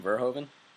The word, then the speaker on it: Verhoeven: 1987 film by Paul, I don't know how to say his name, Verhoeven? – Yeah.